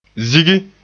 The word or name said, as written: zigi